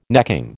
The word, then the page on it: necking: American English